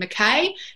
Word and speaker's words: McKay: You're in the US, you might pronounce my name McKay, if that gives people a bit of clue on how to spell it.